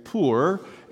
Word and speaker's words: Pur: Purim in the Hebrew, or Pur, P-U-R as it stands, but we pronounce it Pur, is the Hebrew word for dice.